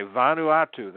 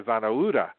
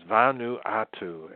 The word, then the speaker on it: Vanuatu: But anyway, Vanuatu, that is, everybody repeat for me, Vanuatu. Took me a year to say it properly because I kept saying Vanuatu. And it's Vanuatu.